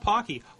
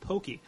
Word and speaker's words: Pocky: But on this last week's digital photo showdown thing they did, they just randomly started talking about Pocky. Although they called it Pokey. I don't see how that's even phonetically close.